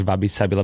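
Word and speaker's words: wabi-sabi: Mne sa jednak páči, ako vyslovuješ Wabi Sabi, lebo ja teda vám tak tvrdšie, ale to zase pramení z toho, odkiaľ som a z toho, odkiaľ si ty vieš, ty zavaš Wabi Sabi.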